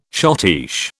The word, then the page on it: schottische: British English